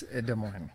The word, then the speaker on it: Des Moines: The S is silent. It's Des Moines.